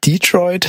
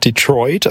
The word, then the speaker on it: Detroit: Ich habe gelernt, das heißt nicht Detroit, sondern Detroit, also äh, Stress on the second, äh, auf die zweite Silbe.